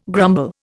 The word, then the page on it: grumble: Indian